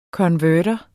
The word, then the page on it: konverter: [ kʌnˈvœːdʌ ]; [ kʌnˈvæɐ̯dʌ ]